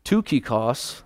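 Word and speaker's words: Tychicus: He says, when I send Artemis and, Tukikos, that's how you pronounce that name.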